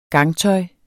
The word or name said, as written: gangtøj